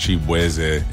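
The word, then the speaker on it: Chibueze: My last name is actually pronounced Chibweze. And just growing up in Virginia, like everybody just did not know how to enunciate my name. So they was just like, Shaboozy.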